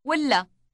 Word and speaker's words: ولا: ولا ولا. – Note that the L sound is doubled in length.